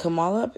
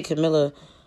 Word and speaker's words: Kamala: Kamala. Um, is it Kamala? I heard people say Camilla. – Yeah, everybody pronouncing it different. – Yeah, I'm gonna say Kamala because that sounds correct. Um, I'm gonna say Miss Harris actually.